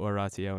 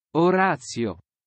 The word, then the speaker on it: oratio: Lectio, meditatio, oratio, and contemplatio.